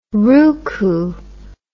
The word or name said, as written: roucou